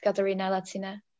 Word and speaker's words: Katerina Laciná: In Czech it would be Kateřina Laciná, but for internationals it's Katerina Laciná.